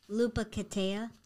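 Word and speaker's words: Lopetegui: How do you say his name? – Lepecheji. – Perfect. I'll go with that.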